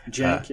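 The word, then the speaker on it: Cenk: Well, it reminds me of that, what's that, Chink Uyghurs? – Jink, yeah. – Yeah, I mispronounced his name when we were doing an interview with Matt.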